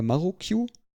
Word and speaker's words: Marukyu: Ähm, der, der, das heißt nämlich äh, sozusagen in, in Japanisch wäre das äh, äh, Marukyu als, als ausgesprochen, äh, Maru für, für Kreis oder rund und Q für 9.